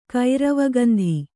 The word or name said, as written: ಕೈರವಗಂಧಿ